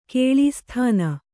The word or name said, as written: ಕೇಳೀಸ್ಥಾನ